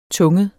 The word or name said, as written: tunget